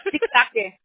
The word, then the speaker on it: Sitake: Sitake.